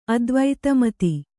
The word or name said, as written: ಅದ್ವೈತಮತಿ